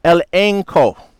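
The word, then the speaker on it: elenko (ἐλέγχω): So this word reprove is a Greek word pronounced elenko.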